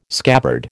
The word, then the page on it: scabbard: American English